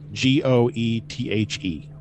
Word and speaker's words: Goethe: the Earl King comes from a poem uh, by I'm a this name Goth Geth G O E T H E. Oh, Goethe. Yeah, totally. There's an R in there. – it's just how it's pronounced. He's I I know Goethe.